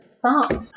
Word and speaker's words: Psalm: No, there's no S in that one. – Yes, there is. – No, it's just Psalm.